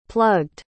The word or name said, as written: plugged